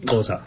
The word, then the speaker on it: Xhosa: Also nur falls Fragen kommen sollten, das Geräusch eben, das war keine Störung in der Aufzeichnung, sondern das spricht sich so, ja? – Ja, genau, Xhosa.